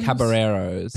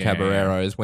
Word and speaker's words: Caballeros: Although it's Spanish, so those L's are not pronounced. You racist. – Okay. – The Three Caballeros. – Caballeros.